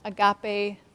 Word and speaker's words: agape: That's the Greek there up top, and agape below is the pronunciation.